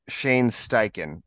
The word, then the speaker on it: Steichen: yeah, let's start with uh, Shane Steichen.